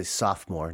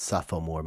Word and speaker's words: sophomore: I think most Americans wouldn't know that there's a third O in there. So we say sophomore, not sophomore, but you spell it sophomore.